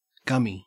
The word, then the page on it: gummy: Australian English